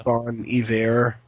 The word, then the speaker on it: Bon Iver: Bon Iver, I guess as people pronounce it, is the right way to pronounce it, but I say Bon Iver.